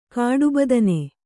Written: ಕಾಡುಬದನೆ

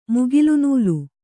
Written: ಮುಗಿಲುನೂಲು

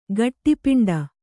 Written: ಗಟ್ಟಿಪಿಂಡ

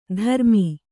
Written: ಧರ್ಮಿ

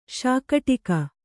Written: ಶಾಕೋಟಕಿ

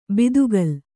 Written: ಬಾತುಗಾಲು

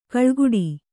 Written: ಕಳೆಗೊಡು

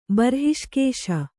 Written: ಬರ್ಹಿಷ್ಕೇಶ

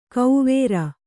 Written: ಕೌವೇರ

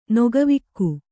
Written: ನೊಗವಿಕ್ಕು

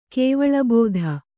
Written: ಕೇವಲಬೋಧೆ

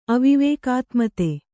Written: ಅವಿವೇಕಾತ್ಮತೆ

